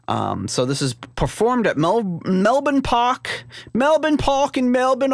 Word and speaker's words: Melbourne: I know enough about Australian to pronounce Melbourne properly.